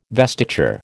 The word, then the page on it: vestiture: American English